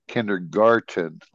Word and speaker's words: kindergarten: But Another word it, is they pronounce it kinder kindergarten, like with a T.